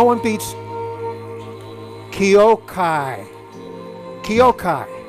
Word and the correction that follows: Kyorai: How you spell that? – K-Y-O. – Yeah. – R-A-I is Kyorai. I'm sorry. Oh. I must humbly apologize to Kyorai. Oh. K-Y-O-R-A-I. Kyorai.